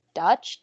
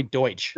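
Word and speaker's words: Deutch: So for my Brenda, I picked Zoe Dutch. – I think it's Zoe Deutsch. – Deutsch. Well, if you're pronouncing it the way it looks.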